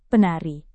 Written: penari

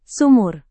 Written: sumur

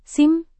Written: SIM

